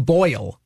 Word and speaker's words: Boyle: Can you tell me one fact about Tim Boyle? – No. It rhymes with Royal.